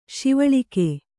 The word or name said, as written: ಸವಲಕ್ಕ